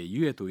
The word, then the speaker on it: 月: あそうか、あいいなみたいなね、井上純さんいいなみたいなところがあるんですが、でもそれ以上にこの月と書いてゆえと読むこの人がとても素敵な人なので、夫婦まとめて応援してあげたくなってしまう本なんですよ。